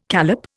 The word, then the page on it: callop: Indian